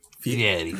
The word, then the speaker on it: Fieri: Don't listen to him, Guy Fieri. You follow your heart. – Excuse me. It's Fieri. – Fieri. It's Guy Fieri. – It's like a Yeti.